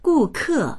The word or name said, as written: gu4ke4